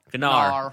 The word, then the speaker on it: Gnarr: G-N-A-R-R. You tell me how to pronounce that. – Gnar. – Gnar. – Yeah, there you go. – G-N... – A-R-R.